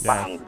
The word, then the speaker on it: Hampang: Ano tong activity niyo na entitled Hampang? Tama ba ang pronunciation ko? Hampang? – Ay, yes po. Yes. Hampang.